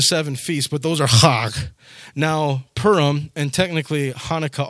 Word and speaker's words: Chag: Those particular feasts, those holidays are known as, I'm going to try to do this right, I don't speak Klingon or Hebrew, but it's Chag. That's what it sounded like when I heard it pronounced on YouTube. Chag. It's C-H-A-G.